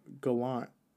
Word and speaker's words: Gallant: I was talking about, how do you pronounce his name? Gavan? – No. Gallant.